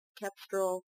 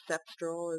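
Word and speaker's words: Cepstral: Still using Eloquence as my primary speech, but I do have, Kepstrel, as they pronounce it, or Sepstrel, as JAWS pronounces it.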